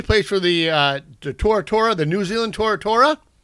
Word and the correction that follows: Tuatara: Tuatara. – Tuatara.